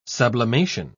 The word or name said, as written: sublimation